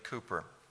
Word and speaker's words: Cowper: In your hymn book, you have one hymn by a man named William Cooper. You spell it C-O-W-P-E-R.